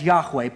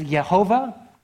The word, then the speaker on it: Yahweh: And so the most common way we end up pronouncing this and where translations choose to leave this in, the most common way they translate that is Yahweh. But just for your education, I want you to know there's other ways that you might hear people pronounce it, and they're not necessarily wrong. So another common way you might hear it pronounced is Yehovah or even Yahuwah. And then a shortened form of it would be Yah.